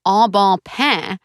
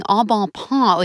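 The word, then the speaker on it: Au Bon Pain: I realized I had trouble pronouncing the name of the exquisite soup, sandwich, coffee, salad chain, En Bon Pain, For years, I'd been saying en bon point, like a freaking idiot loser.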